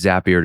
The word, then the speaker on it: Zapier: That's the reason why Zapier rhymes with happier. Bet you didn't know that. Now you know how to say it the right way, too.